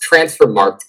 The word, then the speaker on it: Transfermarkt: According to his Transfermarkt page, it's pronounced Transfermarkt, goddammit.